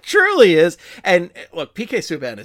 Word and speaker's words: Subban: Subban, who, again, somebody who, much like Nazem Kadri, I have trouble pronouncing because it's always Subban, and I always say Subban because that's just how my brain thinks it should be pronounced. And it's like, no, it's Subban. It's like, okay, I got to think Canadian with this.